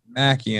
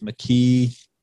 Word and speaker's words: Mckie: People try to call me Mackie and they just butcher it. Mckie. Mckie.